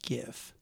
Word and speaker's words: GIF: Yes, it's pronounced GIF.